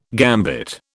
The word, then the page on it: gambit: British English